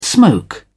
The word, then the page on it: smoke: British English